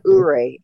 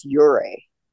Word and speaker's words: Ouray: It's not Ouray, it's Ouray.